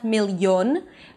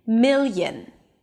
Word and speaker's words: million: It begins with a y sound, a schwa, although it's spelled with an O. Okay, there is no O, it's not million, but mil y, and then an N at the end. Million. Million. The primary stress falls on the first syllable, so it's higher in pitch.